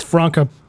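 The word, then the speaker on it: Franka: Franca Potente, I thinking is how you say her name, is what nationality? – German. – There you go. – It's Franca. – Franca. There we go.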